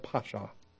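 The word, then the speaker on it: pasha (פשע): The term is called pasha, or pasha. Make the long A the first one and the short A the second one. You have pasha. P-A-S-H-A, and then the apostrophe.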